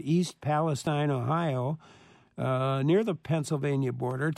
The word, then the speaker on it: East Palestine: But on February 3rd in East Palestine, this is how they pronounce it there, a small town of about 5,000 residents in Ohio near the Pennsylvania border, a freight train derailed.